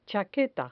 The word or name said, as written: chaqueta